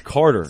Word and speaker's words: Kotter: Is it Carter or is it Carter? – It's Carter, but.